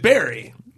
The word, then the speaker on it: Bury: It's spelt bury. – It does. – But it's bury. Like, you buried someone, you got buried.